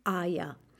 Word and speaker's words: Ei: Yo necesito huevos, los huevos, die Eier. Ese es el plural, por supuesto. El, singular, el singular de Eier es das Ei. Se escribe EI. E i y se dice Ei.